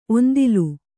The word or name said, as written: ಒಂದಿಲು